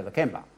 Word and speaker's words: Lakemba: sugar plantation, transplanted himself to Sydney, and he named it Lakemba, but it's not spelt with the B over there. There's no B, but you still say Lakemba, okay? Here, he put a B in to help us with our pronunciation.